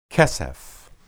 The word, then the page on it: keseph: Hebrew